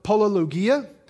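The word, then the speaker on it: polylogia: With many words, that phrase many words is, again, the Greek word polologia, which rhymes with diarrhea, which you would be surprised how many scholars, biblical scholars, people that write commentaries, pick up on this.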